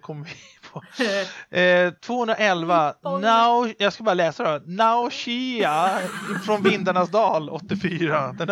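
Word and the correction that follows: Nausicaä: Hur uttalar man det? Jag säger Nausicaa. Vindarnas dal.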